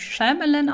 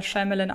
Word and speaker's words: Shyamalan: Ich habe vor einiger Zeit mal ein Interview mit ihm führen dürfen und wurde im Vorfeld dazu angehalten, seinen Namen Shyamalan auszusprechen. Und wer meine absolute Lieblingsserie Community kennt, der erinnert sich ja auch an eine Szene, in der Abed darauf verweist, dass er Shyamalan ausgesprochen wird.